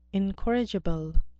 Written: incorrigible

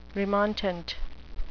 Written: remontant

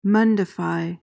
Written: mundify

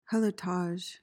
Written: helotage

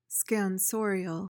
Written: scansorial

